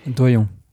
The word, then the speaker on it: Doillon: Doyon. – Doyon. Je l'ai mal prononcé. J'ai toujours dit Doyon. – Non, parce qu'il me semble qu'on dit Doyon, avec les deux L.